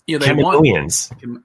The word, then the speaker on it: Kaminoans: Because you switched the vowel sound, I guess, of the A. – Yeah. I want to say Kamino, but then I'm like, no, it's Kaminoans.